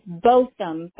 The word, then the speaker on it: Botham: You guys are still operating at the 1910 Botham Jean Boulevard. Did I say that correctly? – It's Botham, but yes, you got it okay.